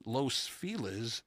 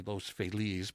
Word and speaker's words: Los Feliz: Uh, in October of 1937, Clinton's home in Los Feliz I like to say Los Feliz, but apparently the locals say Los Feliz.